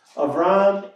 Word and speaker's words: Avram: Now, all of this that I have said is illustrated by today's passage, today's story that we heard of Avram, with a Hebrew pronunciation, Avram and the Lord Yahweh.